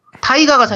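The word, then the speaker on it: タイガ: 대하, 가 음. 일본어로 발음하면 타이가거든요. 음, 네. 그래서 국내명에서는 타이거로 나와요. 아, 네. 그렇게 나오거든요. – 타, 왠지 이름도 비슷하잖아. 타이거 하니까 좀 말이 그렇게 나오니까. 타이가가 사실 일본에서 여, 타이거를 발음 그대로 하면 타이가가 되니까 음.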